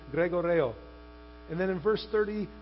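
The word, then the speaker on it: gregoreo (γρηγορέω): That's the Greek word gregoreo. It's like Gregorio. Greg, the name Greg, and then Oreo. Gregoreo, right? So that's the Greek word gregoreo, I think is how you say it, right?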